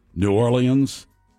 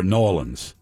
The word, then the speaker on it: New Orleans: There are two ways to pronounce it. New Orleans or New Orleans.